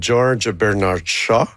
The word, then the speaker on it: George Bernard Shaw: Ben, l'inspiration vient de la pièce de George Bernard Shaw, que j'ai prononcé comme il faut.